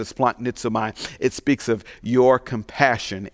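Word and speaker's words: splanchnizomai: Compassion is the Greek word splanknizomai. Splanknizomai. S-P-L-onknizomai. That's how you spell it, okay?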